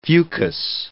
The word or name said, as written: fucus